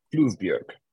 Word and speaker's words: Flyvbjerg: By the way, he claims I'm an honorary Dane because I actually pronounced his name close enough. – Good for you. That's not easy. – It's not an intuitive name. Flyvbjerg. Flugberg.